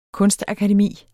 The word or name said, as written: kunstakademi